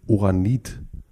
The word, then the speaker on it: Oranit: Äh, du bist in Oranit in Israel aufgewachsen. – Ist hm. richtig ausgesprochen? Das ist richtig ausgesprochen.